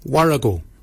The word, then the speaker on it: worragol: Barken is spelled B-A-G-I-N-Y. Barken. Barken is your beard. The upper mouth, we say warrigal. Warrigal. Warrigal is spelled W-O-R-R-A-G-O-L.